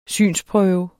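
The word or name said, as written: synsprøve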